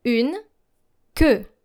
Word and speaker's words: queue: Une, que. Une, que. Now make sure that your E sound is deeper. Que. Une, que. It's not like que in est-ce que, but que.